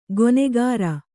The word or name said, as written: ಗೊನೆಗಾರ